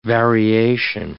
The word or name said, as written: variation